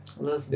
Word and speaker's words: Lisboa: Lisboa. – Is how the locals say it.